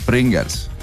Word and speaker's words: Pringles: aga sa eesti keeles hääldad seda ikkagi ju niimoodi , nagu sa kuulad seda inglise keeles , Pringles .